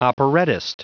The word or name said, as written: operettist